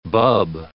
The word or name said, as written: bub